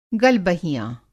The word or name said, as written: गलबहियाँ